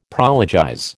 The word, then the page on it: prologize: American English